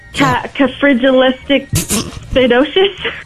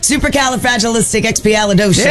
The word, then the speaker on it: Supercalifragilisticexpialidocious: Cafrigilisticexpialidocious. – Almost. – Supercalifragilisticexpialidocious.